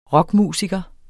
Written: rockmusiker

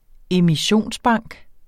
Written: emissionsbank

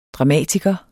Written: dramatiker

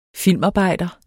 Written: filmarbejder

